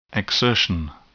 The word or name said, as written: exertion